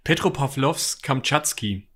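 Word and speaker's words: Petropawlowsk-Kamtschatski: Ähm, also der Heimathafen des U-Boots nennt sich äh, Petropa Petropavlovsk Kamatschki. Ist das richtig nee. ausgesprochen? Petropavlovsk Kamtschatski. Das ist. Kamtschatski, ah, okay, okay.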